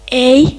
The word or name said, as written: ei